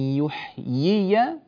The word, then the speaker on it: يحيي: الآية الأخيرة 39، أليس ذلك بقادر على أن يحيي الموتى؟ يحيي عندنا ياءين، عندنا ياءان، فنأتي بالياء الأولى مكسورة كسرا كاملا ثم نفتح الياء الثانية فتحا جيدا، تنبهنا بارك الله فيكم، فهذا يتنبه إليه في النطق.